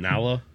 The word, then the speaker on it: Nala: Oh, you mean Nala? – No, we said Nyla because we're from the hood. We didn't say Nala. We didn't pronounce that long that Y with the L attached.